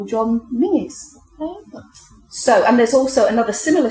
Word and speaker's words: Menzies: Now, British listeners may be aware of the politician Mingus Campbell, whose forename, Mingus, is spelled M-E-N-Z-I-E-S.